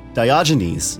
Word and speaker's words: Diogenes: And the National Institute of Health describes Diogenes,